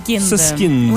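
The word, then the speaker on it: Süskind: Soskind.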